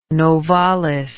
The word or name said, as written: novalis